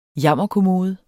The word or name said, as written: jammerkommode